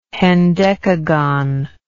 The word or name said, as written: hendecagon